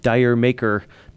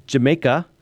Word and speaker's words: Jamaica: 99.9% of people, I think, would call it Dire Maker, but it's actually pronounced Jamaica, and it's built off of a joke.